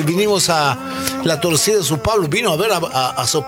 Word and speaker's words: São Paulo: Dice São Paulo, ¿está bien dicho así? – Perfecto.